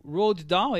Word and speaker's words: Roald Dahl: Sinopse parceria com a Disney, adaptação do livro de Roald Dahl, é assim que pronuncia? – Sim, Roald Dahl.